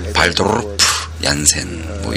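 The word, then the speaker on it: Wouter Janssen: 어, 슈트 얀센과 어, 데이빗의 그 말을 빌리면 발도르프 얀센 뭐 이렇게 그 발음이 되는데요. 슈트 얀센과 발도프 얀센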